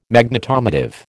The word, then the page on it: Magnetomotive: American English